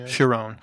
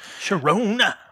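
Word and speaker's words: Charon: So him and how do you pronounce Lance Reddick's character? Is it Chiron? – Chiron. – Chiron.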